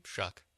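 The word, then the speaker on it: Shough: I mean, it's Tyler Shue, probably. – Shuck. – Exactly. – Doesn't matter. – Exactly. – It rhymes with suck.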